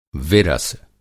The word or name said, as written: विरस